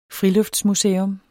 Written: friluftsmuseum

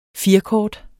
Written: firkort

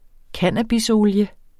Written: Cannabisolie

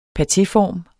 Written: patéform

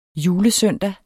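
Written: julesøndag

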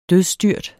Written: dødsstyrt

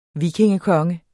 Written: vikingekonge

